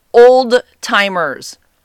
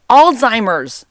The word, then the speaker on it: Alzheimer's: They say Alzheimer's and old timers. What? Where are you coming up with this? Like, how are people coming up with these words? It's Alzheimer's. There's a Z in there.